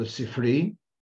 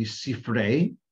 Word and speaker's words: Sifrei: In Yiddish, it's called the Sifri. The more correct pronunciation would be Sifrei.